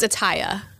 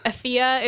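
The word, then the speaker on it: Atthaya: It's Atiya. Yeah, I would have never gotten like that. – Athea is like what it would look like, but phonetically it's Atiya Titical is her name, the 19-year-old.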